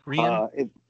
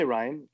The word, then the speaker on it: Rian: Is it Rian? – It's Rian. It's Rian Johnson. Okay. Yeah. But people say Ryan. I've heard Kathleen say it, and I know he accepts it. But like the pronunciation is Rian.